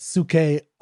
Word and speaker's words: Psyche: I pronounce it Suke, although I don't think that's how Keats would have pronounced it, only because that's how the transliteration is technically from ancient Greek to English would be pronounced suke.